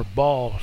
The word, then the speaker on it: Baal: New Living Translation says this: When Ahab got home, he told Jezebel what Elijah had done and that he had slaughtered the prophets of Baal, or Baal sometimes as it's pronounced.